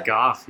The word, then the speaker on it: Goff: Logan, how do you say it's Goff, right? – Goff, yeah. – Goff with the G, dude.